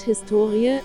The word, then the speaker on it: Historie: Das Wort Historie, HS2IE, die Betonung liegt auf dem O, bezeichnet bis in das 18.